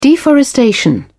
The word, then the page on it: deforestation: British English